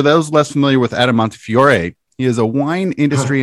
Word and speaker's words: Montefiore: Is it Montefiore? How do you pronounce it? – We say Montefiore. I know in America, some people say Montefiore. We say Montefiore. Okay.